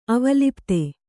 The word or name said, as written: ಅವಲಿಪ್ತ